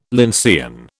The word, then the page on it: lyncean: British English